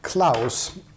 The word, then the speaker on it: Clowes: Nej, det här är en serieförfattare som heter Daniel Klaus. Jag var tvungen att leta upp på internet hur han uttalar sitt namn. C-L-O-W-E-S stavas det.